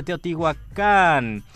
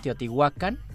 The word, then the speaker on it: Teotihuacán: En español se dice de Teotihuacán, en náhuatl se dice Teotihuacán.